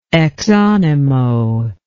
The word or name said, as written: exanimo